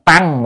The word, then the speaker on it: tấn: Tăng, chữ này có hai âm, một âm là tăng, một âm là tấn. Ở trong cái câu mà oán tấn hội khổ đó quý vị, thì cái chữ này là đọc là tấn.